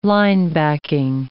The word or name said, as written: linebacking